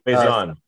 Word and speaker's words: Faison: I mean, Thor, Ramsey, and then Donald Faison. I believe I'm saying his name correctly. – Faison.